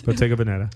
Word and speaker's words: Bottega Veneta: Bottega Veneta. – Yeah, I'm like, yeah. I don't know. – Bottega Veneta.